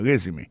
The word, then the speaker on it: resume: I see from your resume. Accent tombé sur premier syllabe là.